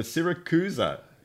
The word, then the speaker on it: Siracusa: I'll be honest, I've never come across in my time, but I had to go and check the pronunciation just to make sure that I got it right. But the Siracusa Grand Prix.